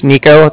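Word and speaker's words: Niko: Okay, yeah, like I said, my name's Nico. That's N-I-K-O. And then I'm calling from the Bank of Cardiff, and that's spelled C-A-R-D-I-F-F, as in Frank Frank.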